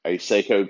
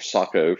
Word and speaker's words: Sako: But on that particular day, it just so happened that I was testing out some ammo in a Seiko quad range, or Sako for those who prefer the correct pronunciation.